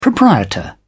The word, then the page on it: proprietor: British English